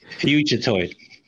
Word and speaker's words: Fugitoid: I'm going to go back and forth between calling Honeycutt and Fudgetoid. I'm not even sure I'm pronouncing Fudgetoid correctly. – Fugitoid. – Is it like Fugitoid? Fugitive? – Okay, Fugitive.